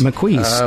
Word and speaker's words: Maquis: Maquis, is it? Maquis? Maquis? – Maquis, I'm pretty sure.